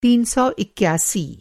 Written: तीन सौ इक्यासी

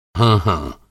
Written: हाँ-हाँ

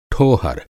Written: ठोहर

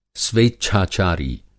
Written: स्वेच्छाचारी

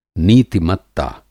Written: नीति-मत्ता

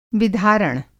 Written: विधारण

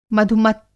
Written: मधुमत्त

